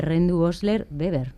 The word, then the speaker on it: Rendu-Osler-Weber: no sé cuántos casos hay de este síndrome de Rendu-Osler-Weber. No sé si lo he dicho bien. – Sí, perfectamente.